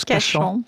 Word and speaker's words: Kashan: Kashan.